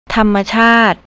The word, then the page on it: tammachart: thai